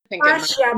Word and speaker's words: fascia: Fascia, but like it's English.